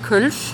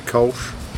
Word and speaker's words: Kölsch: I am so glad that you pronounced the word Köln. – Kölsch, right? – Kölsch. – Because so many people call it Kölsch, and I thought, that's not quite right, is it? – No.